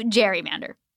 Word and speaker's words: gerrymander: And somewhere along the line, we changed the name to gerrymander, soft G.